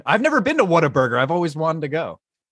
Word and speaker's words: Whataburger: It's not an R in Whataburger. I think you. Whataburger. Oh, it's what's a burger? – Pronounce it right. – Yeah. – Oh, what a burger. What a burger. – It's like, what a burger. Like, it's like if you what ate a burger and you're like, what a burger.